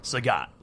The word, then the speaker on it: Sagat: How is it pronounced? – Sagat.